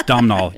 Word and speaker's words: Domhnall: It's Domhnall. You spell it like an idiot.